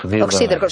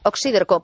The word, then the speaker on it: oxíderco: ¿Oxíderco cómo suena? ¿Oxiderco? – Exacto, y con acento en ahí. – Oxíderco, sí, sí, es esdrújula. Oxíderco, pues en el diccionario de la RAE no aparece.